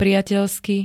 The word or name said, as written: priateľsky